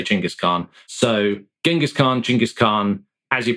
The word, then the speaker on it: Chinggis Khan: I think if you want to, you get Chinggis Khan is how most scholars say it properly, but I'm a pragmatist in this regard, rather than strictly politically sensitive and correct.